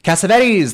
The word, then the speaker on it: Cassavetes: Cassavetes.